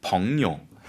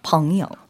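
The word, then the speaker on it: 펑요 (朋友): 펑요. 펑이 이성이고 그다음에 요가 삼성이죠. – 여기서는 네. 살짝 경성으로 발음해 주셔도 괜찮아요. – 아, 그래요? – 그래서 펑요. 이렇게.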